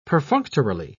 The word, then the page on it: perfunctorily: British English